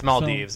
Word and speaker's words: Maldives: It's Maldives.